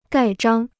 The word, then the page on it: 盖章: gài zhāng